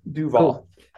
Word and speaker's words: Duvall: And you are Chris Duvall. Did I say it right? Did I mess it up? I know I've just asked, but I've forgotten already. Gosh. – Duvall.